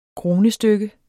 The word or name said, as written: kronestykke